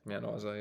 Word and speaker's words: Mount Isa: Like, what, Mount Isa? How do you say it? – Mount Isa, yeah.